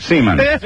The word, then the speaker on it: Seomin: S-E-O-M-I-N, pronounced Seaman.